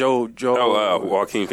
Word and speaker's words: Joaquin: How you pronounce it? – Joaquin Phoenix. – Joaquin. There we go.